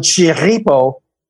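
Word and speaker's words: Chirripó: So right behind me, you see El Chiripa or El Chiripo, which is the local pronunciation for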